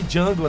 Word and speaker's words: Django: Que, é, que ele fala que o, o, o Alton Goggins fala de Django, né? né? Aí ele fala, o D é mudo, caipira, dá um tiro.